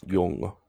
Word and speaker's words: Jung: And then is that how you pronounce it? Jung? – Jung? It is. – Is it? Okay. – Jung.